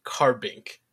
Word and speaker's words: Carbink: I'm literally going to pronounce it how I see it, and it says Carbink.